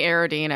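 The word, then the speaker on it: Aroden: At the very least, as you've been informed, the proper pronunciation for Arodine is. – Yes. – It's definitely Arodine. Everybody's been wrong for a really long time.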